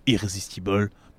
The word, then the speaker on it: Irresistible: Irrésistible. Mais il n'y a pas d'accent, donc il faudrait la prononcer à l'américaine. – Irresistible. – Irresistible.